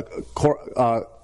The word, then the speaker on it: korban: It's a korban. That's how you say it.